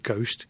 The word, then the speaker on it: ghost: The silent H in the word ghost is the result of a historical hiccup dating back 5 centuries.